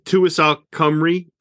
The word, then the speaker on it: Tywysog Cymru: Tywysog Cymru.